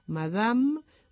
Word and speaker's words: Madame: al final de palabra concretamente, no se pronuncia: Madame, Monique, Nadine, Le Maître, y es la E no pronunciada.